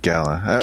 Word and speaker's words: gala: Is it gala or gala? It's gala, right? – I always did gala. I wonder, it's probably a regional thing as to how you pronounce it.